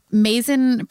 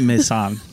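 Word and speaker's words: Maysan: So they were testing this algorithm in the Mazin province of Iraq. – Oh, Paul's listening to this going, that is not how you pronounce that. – No, it's not how you pronounce it. I'm so sorry, Paul.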